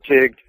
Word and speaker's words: Teague: This took place in Tigg, Texas, which Tigg sounds like it's spelled T-I-G, but it's actually Teague, which is T-E-A-G-U-E, Tigg, Texas.